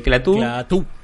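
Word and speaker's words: Klaatu: En este caso tenemos a Clatú o Clatú, si mal no recuerdo es con acento en la U. – Eh, Clatú,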